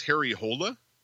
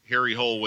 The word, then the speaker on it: Harry Hole: His name actually is Harry Hole, right? Like holy almost. – Right. – But of course, you know, they fucking mispronounce it throughout the whole thing. So it just becomes like Harry Hole, which is hilarious, by the way.